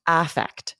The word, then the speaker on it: affect: but it's almost always pronounced affect. We change the vowel.